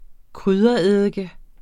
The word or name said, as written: kryddereddike